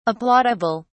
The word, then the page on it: applaudable: British English